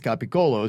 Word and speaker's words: capicola: In Italy, the proper Italian is capicola, pronounced capicola, as we just said.